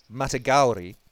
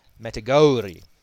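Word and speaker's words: Matagouri: You pronounced the word Matagauri. If you look at the spelling, the O-U should be pronounced Matagauri.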